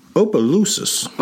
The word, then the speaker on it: Opelousas: So Bobby Dunbar was the first son born to Leslie and Percy Dunbar in, how do I pronounce that town again? – Opelousas.